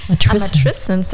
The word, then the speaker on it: Matrescence: Matrescence, das ist Englisch richtig ausgesprochen.